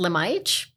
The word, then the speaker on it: Lemajic: Um, my family, my dad is Serbian. Okay. – From Serbia. – So it's pronounced Lemaic, but yeah.